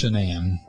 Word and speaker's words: Shanann: Is it pronounced Shanann or is it Shanann? – It's Shanann. No goofy stuff.